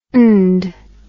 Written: nd

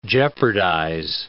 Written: jeopardize

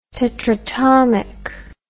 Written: tetratomic

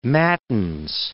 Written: matins

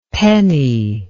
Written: penney

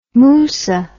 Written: musa

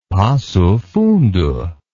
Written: passofundo

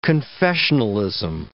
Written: confessionalism

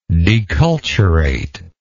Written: deculturation

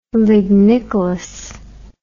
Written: lignicolous

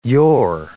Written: you're

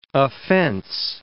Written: offence

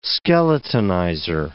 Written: skeletonizer

